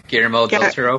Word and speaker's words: Guillermo del Toro: I don't know how to pronounce his name. Guillermo? – Guillermo del Toro.